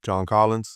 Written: John Collins

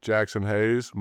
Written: Jaxson Hayes

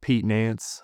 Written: pete NANCE

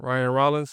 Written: Ryan Rollins

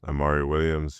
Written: Amari Williams

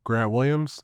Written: Grant Williams

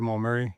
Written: Jamal Murray

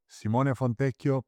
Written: simone FONTECCHIO

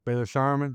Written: Baylor Scheierman

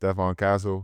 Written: Stephon Castle

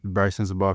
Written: Brice Sensabaugh

